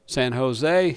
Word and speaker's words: San Jose: It's San Jose. No, down here, when you see a J, it's pronounced as an H. He said, what? They said, yeah, jalapeno, San Jose.